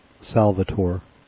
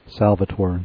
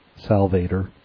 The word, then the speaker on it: Salvator: And the name of the beer is Salvator. Three syllables with slight emphasis on the middle one. Salvator, not Salvator. Like Celebrator, not Celebrator. You know, it ends in A-T-O-R, but, you know, you try to pronounce it like Germans do, not what you would think an English pronunciation is, because that's wrong.